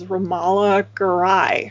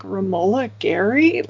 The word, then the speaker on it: Romola Garai: But the film in the future, she's played by Romola Garai, who I always thought it was Romola Garai for the longest time. And I had to like watch this video of her pronouncing her name like over and over again.